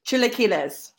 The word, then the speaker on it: Chilaquiles: You're going to pronounce this one for me. Chilaquiles verdes? – Chilaquiles. – Thank you very much.